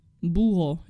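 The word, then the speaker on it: búho: Buho. Ja, okay. El Buho. – Buho.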